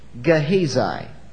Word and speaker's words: Gehazi: His name is Gehazi. Now that's the way it's pronounced to the best of my ability. I've checked that out. There are probably other ways to pronounce it. That's not the way you would pronounce it in Hebrew. But I think in English that's probably close enough. All right? G-E-H-A-Z-I, Gehazi, not one of your more famous Old Testament characters.